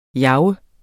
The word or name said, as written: jager